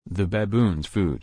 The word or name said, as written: the baboon's food